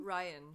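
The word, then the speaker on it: Rian: Wait, is it pronounced Rianne? – Ryan. – I thought Ryan? – Ryan. Rianne? – Oh, okay. – You said Rianne. – Yeah, you said Rianne. Like Rihanna.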